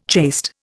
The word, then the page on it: chaste: Indian